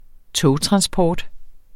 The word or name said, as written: togtransport